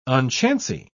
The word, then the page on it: unchancy: British English